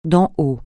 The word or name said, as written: d'en haut